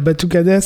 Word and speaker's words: batucada: Je le prononce bien. Qu'est-ce que c'est ? – Oui, la batucada, c'est une percussion afro-brésilienne.